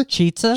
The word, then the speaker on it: Chizza: What, how do you say it? – Cheetza.